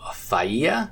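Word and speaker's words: Failla: The art is by Marco Faia, I think it is. It's F-A-I-L-L-A, so I'm giving it the Hispanic pronunciation with the double L sounding like a Y.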